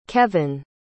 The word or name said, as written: kevan